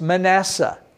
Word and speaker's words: Manasseh: Now maybe you don't recognize it because I'm saying the Hebrew pronunciation. Ephraim, maybe you recognize it that way. Maybe you recognize Manasseh. In Hebrew, Menashe the Ephraim. That's how we say it.